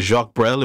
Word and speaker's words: Jacques Brel: Is it Jacques Brel? Am I saying that right? – Yeah. – Okay, thank God, because it's not like Pugnello when I say it.